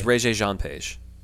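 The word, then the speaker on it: Regé-Jean Page: It's Regé Jean Page.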